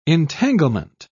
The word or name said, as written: entanglement